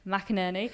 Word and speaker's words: McInerney: But then in PR and in that role, like there's a line in Nora McInerney. Is that how you say her name? – Yes, I feel you like got it right. – McInerney.